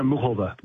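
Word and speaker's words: Muchová: but I can't help but bring up that Karolina Muskova? Is that how we say her name? – And she says Karolina Muhova. – Muhova. – She pronounces it.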